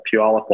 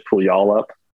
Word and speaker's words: Puyallup: Yeah, I'm in a city called Puyallup, Washington. A lot of people will pronounce it Puyallup, which is in the Pacific Northwest, up in Washington State, the west side of the Cascades.